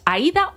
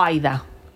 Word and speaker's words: Aída: Bueno, pues es que Aída ha cazado, nunca sé si es Aída o Aida. – Aída. – Aída, sí. Nízar. Es que hay gente que lo pronuncia a Aída. – De hecho, creo que ella misma lo pronuncia como Aída.